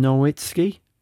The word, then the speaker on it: Nowitzki: Nowitzki, the German.